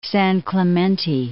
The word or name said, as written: San Clemente